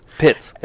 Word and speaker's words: Pitts: And we take Tommy Putz because his last name is pronounced Putz. – Pitts. It's actually pronounced Pitts. – Oh, it is Pitts. – But it looks like Putz.